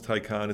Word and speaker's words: Taycan: And this is weird because it's spelled Taycan, but it's pronounced Taycan. It's a German thing.